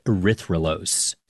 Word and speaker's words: erythrulose: And they scanned it for radio signals of different molecules, which is how they found a four-carbon sugar called erythrulose.